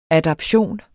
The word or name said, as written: adaptation